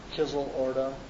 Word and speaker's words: Kzyl-Orda: In Kyorda? Kyorda? – Kizil Ordu. – How do you spell that? – K-Z-Y. Okay, it's right here. K-Z-Y-L-O-R-D-A.